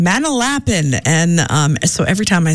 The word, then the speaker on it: Manalapan: originally from Manalapan. Not how you say it, by the way. It's Manalapan. I always remember because growing up, my our neighbor, Ira Silks, and they're every now and then they tune in.